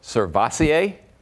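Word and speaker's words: SirVocea: Servassier? Dennis. – He got it. Oh, my God. Can I get a bump or anything? – You get a fist bump. – Yeah, first time. We're never really together. – Servassier. All right, tell us about it. You got the name right.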